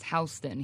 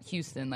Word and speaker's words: Houston: Yeah, I didn't know Nomad until yesterday, but it makes sense because Soho stands for, and it's pronounced Houston here, not Houston, like we would say.